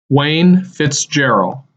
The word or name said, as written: Wayne Fitzgerrell